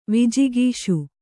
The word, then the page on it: ವಿಜಿಗೀಷು: Kannada